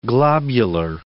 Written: globular

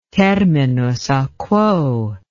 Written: terminus a quo

